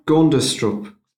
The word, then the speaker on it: Gundestrup: Gundestrup.